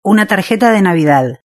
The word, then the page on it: una tarjeta de navidad: Spain Spanish